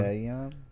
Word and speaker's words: Allegaeon: Like, allegan or alleg... I'm really bad with the allegory. A followed by an E thing. – Allegion.